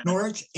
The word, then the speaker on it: Norwich: Norwich. And but you don't pronounce the W. You pronounce it at the RWRR.